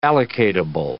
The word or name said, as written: allocatable